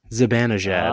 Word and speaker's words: Zibanejad: Zibanejad.